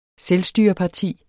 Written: selvstyreparti

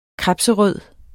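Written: KREBSRØD

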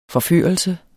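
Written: FORFØRELSE

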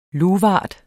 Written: luvart

